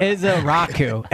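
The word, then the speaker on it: Ezeiruaku: Ezezuka. – You don't get credit until you say the name right. – It's a tough name to pronounce. – Donovan here. – Ezaraku is how you pronounce it. Phonetic.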